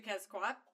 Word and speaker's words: Quetzalcoatl: And okay, I'm sorry, it's actually Quetzalcoatl. – Quetzalcoatl. – We actually don't pronounce the L. – Yeah, you don't say the L at the end, but at the same time, like, I don't know, it's fucking there, so I say it.